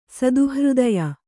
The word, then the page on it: ಸದುಹೃದಯೆ: sadu hřdaye